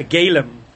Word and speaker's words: golem: When I was a kid, they used to call it, because I grew up in a very Lithuanian Yiddish pronunciation, they used to call me all the time a galem. There'd be a galem.